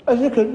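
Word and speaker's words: ذِكر: وكل ما جاء في القرآن الكريم الذكر بكسر الذال ويشمل الذكر الذين يذكرون الله قياما وقعودا وعلى جنوبهم ويتفكرون في خلق السماوات والأرض.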